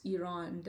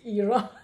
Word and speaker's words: Iran: So I ask my students to pronounce Iran the right way and not Iran. – So step one, pronunciation. – I always say, like, repeat after me, it's Iran.